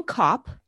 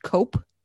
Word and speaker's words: Copp: William Copp, C-O-P-P, it's sometimes pronounced Cope, C-O-P-E, was a shoemaker who emigrated to the colonies in 1635 and bought a small parcel of land on Windmill Hill.